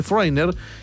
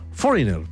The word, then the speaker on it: foreigner: Bueno, oye, aquí tengo a Benito Martínez y dice, pero mi relación, cero mala nada, dice, pero mi grupo relación de aborrecente, foreigner, que significa extranjero, como ya saben se pronuncia foreigner.